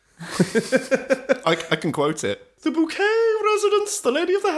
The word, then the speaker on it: Bucket: But it was spelt Bucket, but she insisted that it was actually pronounced Bouquet.